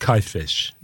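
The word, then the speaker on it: Kajfeš: That's spelled K-A-J-F-E-S. I believe it's pronounced something like Kajfesh.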